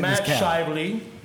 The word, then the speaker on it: Matt Shively: But listen, Matt Shively.